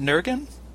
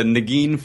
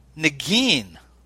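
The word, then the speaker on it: Negin: So how is it that – and by the way, am I pronouncing your leading lady's name right? Is it Nurgen? – You're not, but no one has ever pronounced it correctly. – Tell me. – It's a Nagin Farsad.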